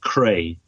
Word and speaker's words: Kreij: And one of the people in the group was a fellow, an engineer by the name of Albert de Krij, K-R-E-I-J, that's how he pronounced it.